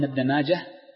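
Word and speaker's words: ابن ماجه: نعم، هذا حديث ابن ماجة، وذكرت لكم قبل أن ابن ماجة المحدثين ينطقونه بالهاء الساكنة مطلقا، حال الوصل وحال الوصل، حال الوصل وحال الوقف، ولا ينطق بالتاء.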